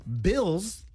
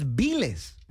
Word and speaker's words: bills: You're trying to say bills, like right, the bills that you gotta pay, pero dices biles. – Uh-huh. – And that is incorrect as well.